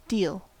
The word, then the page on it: deal: American English